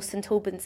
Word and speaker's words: St Albans: I'm here with Kat, Barbara and Jane, and you're from the Sir Optimus International St Albans and District.